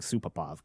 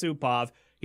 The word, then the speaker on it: Supov: I was expecting Supapov, because that, you know, that my brain was going to autofill the rest of the letters. But no, it's just Supov.